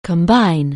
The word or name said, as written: combine